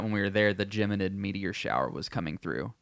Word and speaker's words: Geminid: It's called Geminid Meteor Shower.